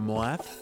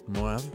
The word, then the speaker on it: MOAD: El nombre del grupo es un acrónimo que se pronuncia MOAD, con espacio M, espacio entre las letras. Pero que significan Mind of a Dragon, MOAD.